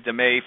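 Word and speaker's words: DMAIC: I guess you pronounce it DME for like a better pronunciation, but there's five different areas to look at.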